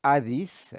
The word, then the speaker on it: Hades: In the Old Testament, the Hebrew word Sheol, the Greek equivalent being Hades or Hades, as we would pronounce it in contemporary Greek, rendered as hell, the grave or the pit, designates the place to which all, both just and unjust, go at death.